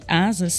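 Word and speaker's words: Asaas: Lembrando que Asas se escreve A-S-A-A-S e conheça a conta digital completa para empresas.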